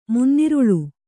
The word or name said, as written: ಮುನ್ನಿರುಳ್